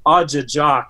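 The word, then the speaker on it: ajijaak: So the word for crane in Ojibwe is pronounced Ajajak.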